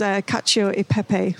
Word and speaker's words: cacio e pepe: which is cacio e pepe.